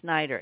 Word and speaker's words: Snider: My main name was Kazonis, and I thought I'd never have to spell it again, but now I'm spelling Snyder, S-N-I-D-E-R.